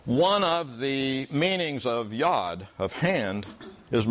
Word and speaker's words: yad: It's spelled Y-A-D and pronounced Yod.